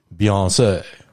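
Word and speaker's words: Beyoncé: Beyonce, rhymes with fiancé, right.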